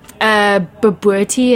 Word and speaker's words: bobotie: So tell me about Boer sausage or biltong, or how do you pronounce B-O-B-O-T-I-E? – Babuerti is the dish, that custard thing.